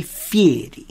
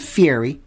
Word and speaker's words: Fieri: I saw a very interesting interview the other day with Guy Fieri. Not Fieri. Fieri. What was that? Fieri. Because you got to say it like that.